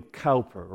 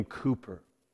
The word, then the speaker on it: Cowper: A man like William Cowper or William Cooper, depending on how the English pronounce it,